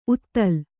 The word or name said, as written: ಊದಲು